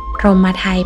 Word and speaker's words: พรหมไทย: พรหมไทยแปลว่าของอันพรหมให้คือของขวัญที่วิเศษที่สุดพรหมไทย